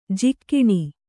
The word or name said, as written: ಜಕಣಿ